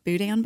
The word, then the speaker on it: boudin: I think they're even cooking up some appetizers like boudin balls, which I'm probably going to say wrong. – No, you said it right.